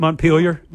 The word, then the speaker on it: Montpelier: Montpelier.